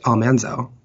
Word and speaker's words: Almanzo: No, I didn't learn that until one of my lives where somebody commented and said it's pronounced Almanzo.